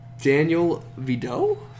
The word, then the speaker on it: Daniel Vidal: Daniel Vidal? Is that correct? – I'm Australian. What do I know? – Oh, former. He was a rugby player from Australia. – That's exactly how you pronounce it.